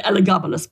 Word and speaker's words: Elagabalus: Elagabalus. Do yes. I say that right? – Yeah, pretty well. I say Elagabalus, but you're probably more correct.